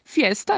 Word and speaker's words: fiesta: Que é festa, mas lembrem-se que esse i tem que ser bem marcado aí na pronúncia, fiesta, para não ficar parecendo o português, né? Falando festa.